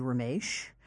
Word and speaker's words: Ramesh: Well, my parents named me Ramesh. Uh-huh. That is the classical pronunciation of those letters.